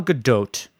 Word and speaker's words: Gadot: Gadot. – Gadot. Gadot. – Gal got it. I get that. – See, no, that you're getting like way off. Gal Gadot, 5'9¼".